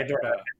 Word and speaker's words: Hedorah: So wait, it's pronounced Hedorah? Wait, how's it pronounced? – So, yeah. Hedorah. – Hedorah, yeah, Hedorah.